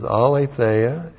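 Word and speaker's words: aletheia (ἀλήθεια): This is P-N-E-U-M-A, pneuma. This is the genitive of the article, T, long E-S. And this is aletheia, A-L, long E-T-H-E-I-A.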